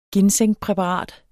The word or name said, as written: ginsengpræparat